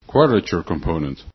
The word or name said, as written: QC